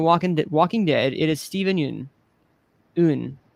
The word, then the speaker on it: Yeun: I've been pronouncing it wrong this entire time. I heard an interview. It is Steven Yeun.